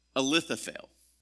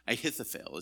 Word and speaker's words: Ahithophel: There's a man that in 2 Samuel, and his name is Ahithophel. I'm sorry, I pronounced that wrong. Ahithophel is his name.